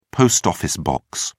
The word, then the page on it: post-office box: American English